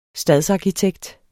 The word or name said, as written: stadsarkitekt